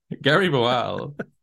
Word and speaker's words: Boal: I don't know how you pronounce the surname. – But I would imagine it's the same as the bowl you eat your cereal out of. – Okay. Well, it's B-O-A-L. Boal. – How else boal. Would you pronounce it? – Well, I speak Spanish, so in Spanish it would be boal. So I automatically see it as pronounced in a different way. – Gary Boal. – Gary Boal. So that's how it's pronounced.